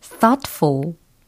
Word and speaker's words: thoughtful: 어, T H O U G H T F U L 이 철자로 어, 과연 어떻게 발음이 되는 단어일까요? thoughtful 이렇게 하면 되겠습니다. thoughtful, thoughtful 사려 깊은, 신중한 이라는 형용사가 되는 거죠. thoughtful, thoughtful 아니고 thoughtful, thoughtful 라고 연습을 해주시길 추천드리고요.